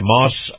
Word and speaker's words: Maus: So remember also that our Truckers Highway and Byway Report is brought to you by Moss Family Automotive. Now, Moss is spelled M-A-U-S,